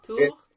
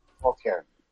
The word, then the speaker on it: Tourtière: Can I help you with the pronunciation of that? – Tortier? – Tour. – Okay. – Tourtière. – I knew that. – Tourtière.